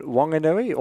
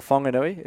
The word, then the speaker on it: Whanganui: So I was born in Whanganui or Whanganui. It's always, it started as Whanganui, W-A. Since I've been born, the local people changed it to WH now, so it's pronounced differently.